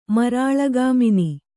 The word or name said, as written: ಮರಾಳಗಾಮಿನಿ